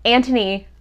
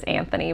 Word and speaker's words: Antony: I realized, Antony and Anthony spelled the same way. So I was like, oh, okay. I've been reading it in my head as Anthony, but it is Antony.